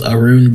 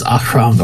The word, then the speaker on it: Ahroun: I had started off with Arun because that was what I heard all the time. Like, if you go on YouTube or look up anything werewolf, that's how they say it. Yeah, no. Now saying it so much as Ahroun or Ahroun sounds better. It just sounds better in the feel of the game, in the world, and just the general vibe.